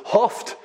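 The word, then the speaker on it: houghed: So I'm going to go with hoft, and I put in my notes, sounds like coughed, to make sure which word I'm using here.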